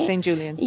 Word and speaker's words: St. Julian's: Right now, we are in St. Julian. Am I saying it right? – St. Julian's. – Yeah, at St. Julian's.